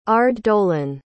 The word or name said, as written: aurddolen